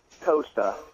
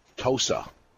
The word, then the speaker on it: Tosa: Tosa. – Tosa.